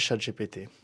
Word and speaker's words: ChatGPT: Moi, je pense qu'il faut apprivoiser le chat et donc on peut prononcer ChatGPT.